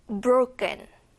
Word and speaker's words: broken: 그 다음은 broken. broken 아니고요. 쌍기역에 가까운 발음으로 들려요.